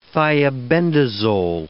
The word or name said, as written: thiabendazole